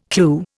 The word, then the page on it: clue: Indian